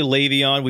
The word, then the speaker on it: Le'Veon: It's actually Le'Veon. We've been going back and forth. Is it Le'Veon? Is it Le'Veon? The listeners had the last laugh when it came to our pronunciation of Le'Veon Bell. It's actually lay, not le.